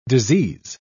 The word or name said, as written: disease